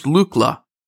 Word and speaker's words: Lookla: So it's pronounced Lookla. – Lookla, yeah.